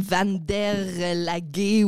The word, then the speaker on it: Vandelac: Donc, euh, ouais, c'est Est de la France, Flamand, tout ça dans, cette région-là, ils prononcent plus comme Van der Lague, ou en tout cas, l'origine de mon nom de famille se prononcerait plus comme ça, mais ça s'est vraiment plus francisé, euh, comme le français qu'on connaît euh, au fil des générations.